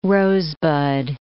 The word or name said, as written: rosebud